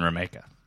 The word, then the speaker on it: Remeka: Our guest this week is the fantastic, hilarious, charming, handsome, effervescent Ben Remeka. – Ben Remeka, that's right. – Wow. That's rhymes with Jamaica. – Mm-hmm. Rhymes with Jamaica. – Mm-hmm. Not Remeka. – Not Remeka, right. Not Ramiaka.